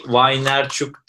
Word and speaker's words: Vaynerchuk: İşte Gary Vaynerchuk diye okunuyor. Vaynerchuk falan.